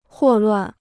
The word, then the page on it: 霍乱: huò luàn